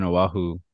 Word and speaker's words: Oahu: Yeah, and I actually went to school over in Oahu for college.